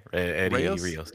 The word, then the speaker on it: Eddy Rios: Yeah, Eddie Reyes.